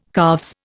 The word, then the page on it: calves: Indian